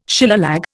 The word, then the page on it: Shillelagh: Indian